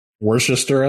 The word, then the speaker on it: Worcestershire: There might be other beverages that have it too, but a Caesar has vodka, a mix, hot sauce, and I can't pronounce this, so sorry if I goof it, but the Worcestershire sauce. – Worcester, I think. – There we go. You got it better than I did.